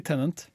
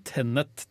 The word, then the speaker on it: Tenet: Den skal hete Tennet. Ikke Tenant. Ikke Tenant men Tenet Tenet?